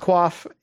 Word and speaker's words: coif: C-O-I-F? – Yeah, it's coif. Yeah, like coiffing, yeah, your hair. So there's like coif, there's a cloth coif and a male coif.